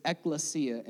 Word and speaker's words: ekklesia: The word church is ekklesia in Greek. Ekklesia or ekklesia, that's the way I pronounce it.